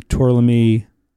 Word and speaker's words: Tourlami: Is there a big difference between using Tourlami? I said it right. – You did. – Tourlami and traditional butter.